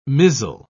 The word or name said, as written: mizzle